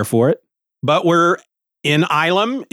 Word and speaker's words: Ylum: a lunar refuge named Ilum. It's spelled Y-L-U-M, and it's apparently a shortening of the word asylum.